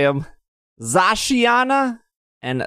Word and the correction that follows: Zacian: I think I did Might There's no A at the end of the Zacian. – Oh, Zacian? Sorry. Zacian and Zamazenta.